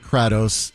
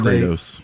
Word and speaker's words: Kratos: And so they have decided to recast Kratos. And it's Kratos.